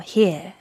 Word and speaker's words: here: Here에 그 듣는다 R 발음을 또 안 하네요. 영국 발음이. – 안 하죠. Here.